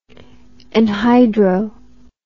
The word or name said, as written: anhydro